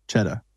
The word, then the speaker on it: Chetta: Chetta. Like cheddar cheese.